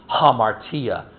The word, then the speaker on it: hamartia: Amartia is sin. All right? Amartia. Some people pronounce it with an H because the A has an aspirant on it. Hamartia.